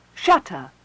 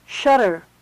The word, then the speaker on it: shutter: In general American, T can be voiced when it occurs between vowels at the end of a stressed syllable. For example, shutter. – Shutter. – It may sound identical with D.